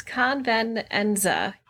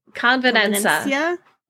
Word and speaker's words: Convenenza: Convenzina. Nope. There are just too many syllables in this. Convenenza. Convenenza. There we go. – How do you spell it? – C-O-N-V-E-N-E-N-Z-A. Convenenza.